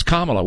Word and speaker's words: Kamala: It's Kamala.